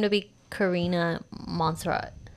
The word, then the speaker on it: Montserrat: Oh, Montserrat.